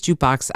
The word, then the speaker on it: JKBX: So we pronounce it Jukebox, but it's spelled JKBX. So think like a stock ticker symbol.